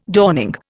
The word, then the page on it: dawning: Indian